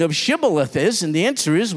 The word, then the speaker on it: Shibboleth: It says, they would say to them, Jephthah's men would say to the Ephraimites, say now, Shibboleth. Say that word, Shibboleth. But he said, Sibboleth. The Ephraimite would say, Sibboleth. See, he couldn't pronounce the word correctly.